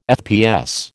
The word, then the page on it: FPS: American English